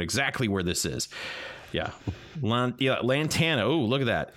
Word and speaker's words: Lantana: Lantania. – I'm probably totally mispronouncing that. – Lantana. – Lantana. Yeah, Lantana, I think, is probably closer to it.